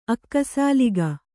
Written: ಅಕ್ಕಸಾಲಿಗ

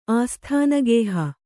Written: ಆಸ್ಥಾನಗೇಹ

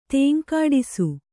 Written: ತೇಂಕಾಡಿಸು

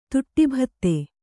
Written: ತುಟ್ಟಿಭತ್ತೆ

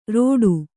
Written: ರೋಟು

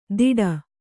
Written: ದಿಡ

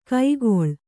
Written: ಕೈಕೊಳ್

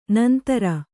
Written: ನಂದುರಿ

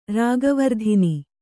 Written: ರಾಗವರ್ಧಿನಿ

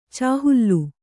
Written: ಚಾಹುಲ್ಲು

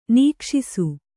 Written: ನಿಕ್ಷಿಸು

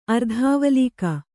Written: ಅರ್ಧಾವಲೀಕ

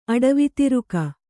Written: ಅಡವಿತಿರುಕ

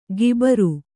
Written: ಗಿಬರು

